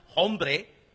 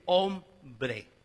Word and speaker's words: hombre: We don't pronounce hombre, hombre. We say hombre. It's a silent H.